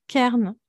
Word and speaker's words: Cairn: vous aurez plus de chances de chercher sur Kern, du coup, d'avoir des ressources en, français, en psychologie sur, sur Kern. Ça s'écrit C-A-I-R-N.